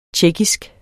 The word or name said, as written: tjekkisk